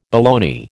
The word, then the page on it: baloney: American English